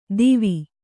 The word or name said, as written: ದೀವಿ